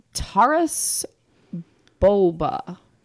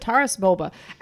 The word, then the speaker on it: Taras Bulba: So the label on mine is telling me it's the Taras Bulba, I think is how we've agreed to pronounce that. Taras Bulba, extra hoppy ale.